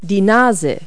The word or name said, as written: nose